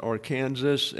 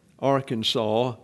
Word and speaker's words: Arkansas: Colorado pronounces it Arkansas, as does the state of Kansas. They call it Arkansas down in Arkansas and in eastern Oklahoma.